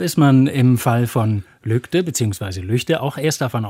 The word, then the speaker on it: Lügde: Und wenn man davon ausgeht, dass ein deutscher Ortsname so ausgesprochen wird, wie die gewöhnlichen Ausspracheregeln anzuwenden sind, dann würde man erstmal sagen, der Ort heißt Lügde. – Ja. – Weil das G nach einem Vokal als ein G oder ein K gesprochen wird.